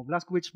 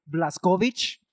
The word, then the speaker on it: Blazkowicz: De que este, los gringos lo pronuncian como Blaskovitz, Blaskovitz, Blaskovitz. Y de repente encuentras a una alemana que te dice Blaskovich. Y es de... Ah, así se pronuncia.